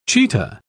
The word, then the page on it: chetah: British English